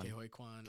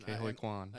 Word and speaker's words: Ke Huy Quan: Kwan. – Ke Huy Quan. – Ke Huy Quan.